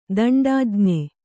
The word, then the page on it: ದಂಡಾಜ್ಞೆ: kannada